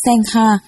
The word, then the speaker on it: Sangha: Tăng, nguyên là tăng già, tiếng Ấn Độ đọc là Sangha, dịch nghĩa ra chữ Hán là hòa hợp chúng, cùng chứng được lý vô vi giải thoát, gọi là lý hòa.